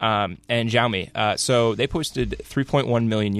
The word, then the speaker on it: Xiaomi: How do you say that? – Xiaomi. – Xiaomi. Okay.